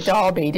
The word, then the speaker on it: Derby: You pronounced it properly. He said Derby.